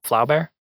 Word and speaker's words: Flaubert: F-L-A-U-B-E-R-T. – Is that how you say it? Flaubert? – I don't know.